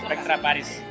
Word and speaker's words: Spectra Paris: A ver, Spectra Paris, ¿quiénes son? – Pues Spectra Paris son un proyecto italiano. – Lo he dicho bien, ¿no? – Sí, Spectra Paris. Yo es que he dicho Spectra Paris.